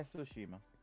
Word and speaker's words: Tsushima: We have new release dates for The Last of Us Part 2 and The Ghost of Tsushima. I hope I'm pronouncing that right. I really do. – Yeah, Tsushima.